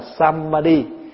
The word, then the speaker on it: samadhi: Chữ tam muội là tiếng phiên âm, còn cái chữ nguyên thủy là samadhi hay là samadhi, tiếng Phạn đọc là samadhi, mình đọc là tamadhi, hay là có chỗ phiên âm là tamabhadhi.